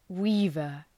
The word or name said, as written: weaver